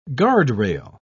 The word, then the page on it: guardrail: British English